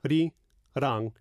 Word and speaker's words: Arirang: So you can almost take them one at a time. – Yeah. – A-ri-rang, then just put them together. – Yeah. – Arirang. – Yes. – So you don't say Ari. – No. – A-ri. – Yeah. – It's not Arirang, it's Arirang. Arirang.